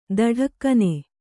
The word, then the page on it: ಥಟಕ್ಕನೆ: Kannada